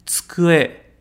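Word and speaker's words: tsukue (机): E o outro kanji, tsukue, tsukue, que significa mesa ou escrivaninha.Toma um pouco de cuidado com a pronúncia do tsukue, tá? A gente não costuma falar muito tsukue, tá? É como se fosse um tsukue, ok? Tsukue, tsukue, beleza?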